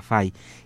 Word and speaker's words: Fay: en esta población de fe o fa, fai, como se escribe F-A-Y, fai, y que por eso él después tomó esta partícula en su nombre, Julien Dufay, eh, Guillermo de Fay.